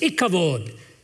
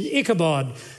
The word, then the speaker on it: Ichabod: He takes the Hebrew word kavod, and so when the glory departed, it was ikavod, or what we would say in the anglicized pronunciation, Ichabod, that the substance has gone from the people.